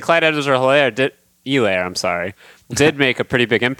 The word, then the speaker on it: Edwards-Helaire: And I did learn that, I guess, we're not pronouncing the H, Clyde Edwards-Helaire, they kept saying on the broadcast, which, you know, I'm into it.